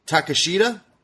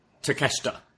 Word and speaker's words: Takeshita: Konosuke Takashita? Takeshita.